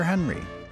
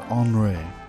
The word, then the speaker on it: Henry: In front of that, Pierre Henry, or Henri, depending on your pronunciation.